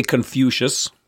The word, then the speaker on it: Confucius: Con- how do you pronounce that in English? – I say Confucius.